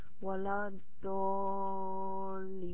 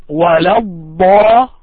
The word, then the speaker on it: ولا الضالين: You say it this way. وَلَا الضَّالِّينَ. That's the way that Prophet Muhammad صلى الله عليه وسلم heard it from Jibreel عليه السلام. So try to read that one more time, please. وَلَا الضَّالِّينَ. And prolong the pronunciation. Make it long.